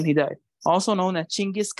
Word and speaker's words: Chinggis: Like Chinggis or Chinggis? – Well, that's how you're supposed to pronounce Genghis. – Mm-hmm. – You got me? That's how it's supposed to be said.